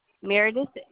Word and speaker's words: Meredith: K-E-N-Y-A. – And the spelling of your last name? – Meredith, M-E-R-E-D-I-T-H.